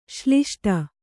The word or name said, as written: ಶ್ಲಿಷ್ಟ